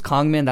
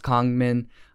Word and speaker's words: Kangmin: My name's Kongmin. That's how I pronounce it. It's spelled Kangmin, but pronounced Kongmin. In Korean, it's Kangmin, but you know, English, if you make it more Englishy, it's Kongmin.